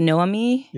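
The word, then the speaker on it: Noémie: I believe her name is pronounced Noemi.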